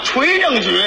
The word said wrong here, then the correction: 邮政局: 啊，您那说错了。 – 怎么？ – 那字念邮政局。